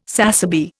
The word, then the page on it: sassaby: Indian